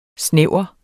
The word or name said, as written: snævre